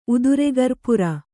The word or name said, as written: ಉದುರೆಗರ್ಪುರ